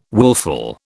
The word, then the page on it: willful: British English